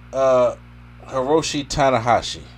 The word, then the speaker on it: Tanahashi: MJF taking on Hiroshi Tanahashi.